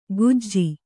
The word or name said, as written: ಗುಜ್ಜಿ